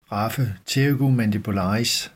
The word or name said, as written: raphe pterygomandibularis